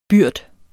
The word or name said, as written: byrd